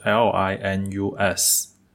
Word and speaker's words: Linus: 他是一个芬兰人，名字拼法是 L I N U S。 Linux 其实也就是跟它那个操作系统 Linux 是差不多的发音，只不过是中文的书籍为了更好的分辨作者和他的操作系统，所以对操作系统还是用 Linux 这种英文的描写，对于作者就翻译成中文叫做林纳斯。